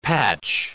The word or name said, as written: patch